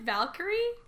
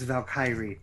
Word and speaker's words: Valkyrie: It's not really Valk, it's Valkyrie. – Valkyrie.